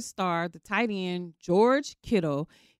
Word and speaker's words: Kittle: I'm like, you say Kittle.